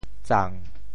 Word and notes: 赠: zang7; Teochew

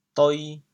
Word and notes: 底: doi2; 白; 潮阳

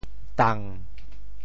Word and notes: 垌: dang7; Teochew

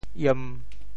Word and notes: 醃: iem1; 潮州; 文

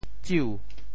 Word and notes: 酒: tsiu2

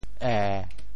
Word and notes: 耶: ê7

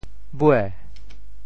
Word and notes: 尾: bhuê2

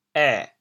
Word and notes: 華: ê6; 潮阳

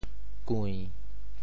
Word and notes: 柜: kui~7

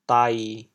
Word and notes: 事: dai7; 潮阳

